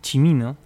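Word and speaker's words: Cimino: Aquí me mandaban un mensaje, me estaban, estaban escuchando y me dicen, se pronuncia Michael Chimino.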